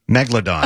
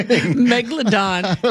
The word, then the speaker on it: Megalodon: Megalodon. – Oh. It's like that's those are two completely different things. – No Megalodon and then a dinosaur.